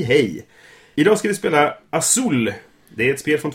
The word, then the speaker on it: Azul: Det heter Azul. Det betyder blå på spanska.